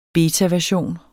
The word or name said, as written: betaversion